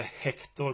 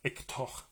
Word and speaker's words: Hector: Dels så är det ett stumpt h-ljud, ungefär som i franskans H, man säger ju inte Hector på franska utan Hector. Det är liksom ett stumt, bara en utandning.